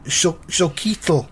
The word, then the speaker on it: Xochitl: And her first name is pronounced X-O-C-H-I-T-L, Zoquitl.